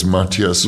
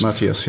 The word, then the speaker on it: Matthias: And Matthias, am I correctly pronouncing his... – He doesn't mind how you say it, but it's Matthias. – Matthias, yeah, yeah.